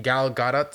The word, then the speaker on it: Gal Gadot: And Gal Gadot.